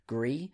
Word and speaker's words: Gris: Ultimate, Dragon Ball FighterZ, Monster Hunter World, Celeste, Into the Breach, The Messenger, Dead Cells, Gris, but I think it's actually pronounced Gris. I found out that apparently Gris is apparently Spanish for grey.